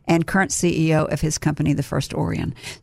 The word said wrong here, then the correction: Orion: So, did I say it right? – No. – Orion. – Oh, I said it wrong again. Sorry. Well, it's like Orion's belt.